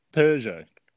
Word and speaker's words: Peugeot: That's how Americans say it. – Really? – The Peugeot. Yeah. – It's Peugeot. – Peugeot. – That's how I say it anyway. – Like a cheetah purrs.